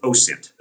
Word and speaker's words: OSINT: government with OSINT. That's how we pronounce it.